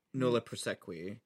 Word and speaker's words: nolle prosequi: Nolle prosequi.